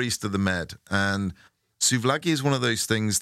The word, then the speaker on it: souvlaki: I think I'm saying souvlaki. Which it is souv, S-O-U-V-L-A-K-I. Souvlaki. – Laggy.